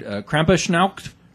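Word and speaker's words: Krampusnacht: And the Eve of St. Nicholas, December 5th, is, God help me if I butcher this German word, Krampuschnacht. – You got it. – Yeah, that's it.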